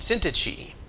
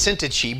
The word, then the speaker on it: Syntyche: This is what Paul is talking about with Euodia and Syntyche. I had to look that up, by the way. The Greek version is Syntyche, but if the German pronunciation is Syntyche, which just blows me away.